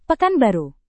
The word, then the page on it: pekanbaru: Indonesian